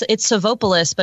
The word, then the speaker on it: Savopoulos: Is it am I saying this right? The Savopoulos family? – Yeah, we actually, it's Savopoulos, but in the beginning, when we were reporting it, you know, you get the name written out by police.